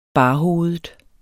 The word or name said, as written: barhovedet